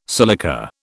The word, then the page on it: silica: British English